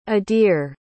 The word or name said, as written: adir